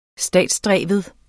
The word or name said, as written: statsdreven